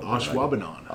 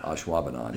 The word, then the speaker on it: Ashwaubenon: You know, Ashwaubenon. – Ashwaubenon.